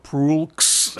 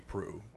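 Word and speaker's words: Proulx: It's not Prulx. It's not Prush. – No. – It's Prue.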